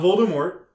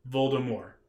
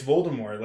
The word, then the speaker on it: Voldemort: Not Voldemort, Voldemort. That's how you say it. That's how you say it. – It depends. – That's how you say it. – I mean, if you read it as Voldemort, you were wrong. – Mm-hmm. – Yeah, no, you were wrong. It's Voldemort. Like, that's like that J.K. Rowling has said, it's Voldemort. You don't pronounce the T is silent.